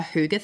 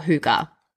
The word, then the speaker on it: hygge: hygge thing where I clock off at the end of the day.